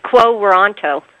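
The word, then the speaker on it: quo warranto: You wrote an article the other day, quo, is it pronounced quo waranto? – Yeah. – Is that how you... – quo waranto. Yeah, it's weird. It's quo waranto.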